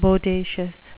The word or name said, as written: bodacious